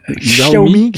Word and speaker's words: Xiaomi: Et puis on continue avec un autre nom que moi j'ai du mal à prononcer, c'est nos amis de Xiaomi, c'est ça? – Xiaomi.